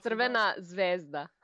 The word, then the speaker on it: Crvena Zvezda: How's the pronunciation on this, by the way? Red Star Belgrade, also known as Crvena Zvezda. Does that sound rough? – Crvena Zvezda. – Ah, I was off.